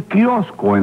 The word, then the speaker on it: kiosco: Me encontré con un amigo mío que tiene un kiosco en la playa de Marianao. – ¿Tú también lo conoces? – No, señor, que se dice así. – Así. Óyeme, entonces, ¿cómo? Un coquio que se llama... Kiosco.